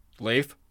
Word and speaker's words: Leif: When you say Happy Leif Erikson Day, how do you pronounce it? – Leif? way you're saying it, yeah. – Okay, so his name is Leif. – Not Leif, yeah.